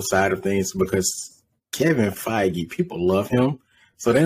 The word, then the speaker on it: Feige: F-E-I-G-E? – Yeah, Feige. – Oh, man. Is that how you pronounce his name? – Yes. – I was just sitting here pronouncing it like beige, feige.